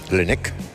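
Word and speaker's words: Laennec: Fue en París en 1816, un médico, René Lenec. – Lenec. – Así lo pronuncio.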